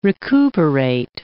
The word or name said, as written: recuperate